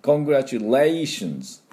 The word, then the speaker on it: Congratulations: で、まあ、この発音の方をですね、やっていきたいと思いますが、最初のこの Congratulation の Con のところは半母音で少し。で、 Congratulations。ここにアクセントがあります。そしてこの Congrat のところをミャーのって、ここに第二、アクセントがありますよね。